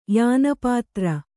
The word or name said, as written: ಯಾನಪಾತ್ರ